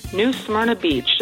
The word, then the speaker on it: New Smyrna Beach: New Smyrna Beach, just like the Bay of Smyrna in Mediterranean.